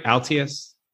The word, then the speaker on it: Altius: Am I saying that right? Altius? Is that right? You are, yes.